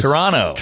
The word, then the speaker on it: Toronto: By the way, in Toronto, they don't pronounce the second T. It's Toronto.